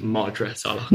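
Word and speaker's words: Madra Salach: I'll stop you there, Raina. Sorry, it's Madras Salak.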